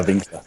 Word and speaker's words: Winkler: Is that how you say your surname, Winkler? – That's perfectly correct. Yeah, Winkler. That's how you pronounce it.